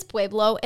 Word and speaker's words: Pueblo: My, it is Pueblo.